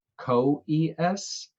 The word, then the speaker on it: Coeus: And it's definitely pronounced Coeus, Coeus.